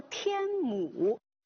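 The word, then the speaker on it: 天姥: 所以现在我要离开山东了，再见了我的朋友们，我要去天母山真真切切地游览我的梦中仙山。让李白发出安能摧眉折腰事权贵这样感叹的这座山天母山，我们把它读作天母，这个老字在其他的一些版本当中它可以写作母，保姆的母这个字。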